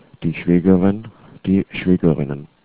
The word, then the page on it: schwaegerin: german